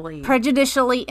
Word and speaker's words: prejudicially: In his third and fourth points of error, Lankford argues that the jury instructions regarding the by omission alternative were prejudiciary. Am I saying that right? – Prejudicially inefficient, erroneous, and misleading because the circuit court failed to accurately define the term crime.